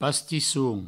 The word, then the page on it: pastisson: pastissoun